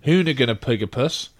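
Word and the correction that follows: Hoonipigasus: That's, I didn't say that right. – What? Hoonapigasus is what it's called.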